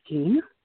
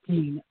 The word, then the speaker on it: Jean: My first name is Jean. – Judy? – Jean. – Jean. Can you spell it? – J-E-A-N.